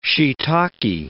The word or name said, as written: shiitake